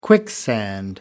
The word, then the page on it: quicksand: American English